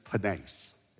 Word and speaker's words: penes: One is penes. It's spelled P-E-N-E-S, would be the English transliteration, penes.